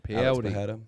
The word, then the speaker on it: Pereira: How do you say that Mexican word? – I think it's Pereira. – Pereira? – Pereira.